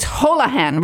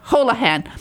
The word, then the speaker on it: Holohan: And so I'm so happy to welcome to Joy on Paper, Kate Hallo, did I say it right? I keep getting it mixed up. It's Holohan, right? – That's right. Thank you for having me. – Okay, Kate Holohan.